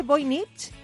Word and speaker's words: Voynich: El códice Voynich, ¿lo he dicho bien? – Voynich. – Voynich, Voynich. es un libro.